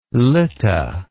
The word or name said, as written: lytta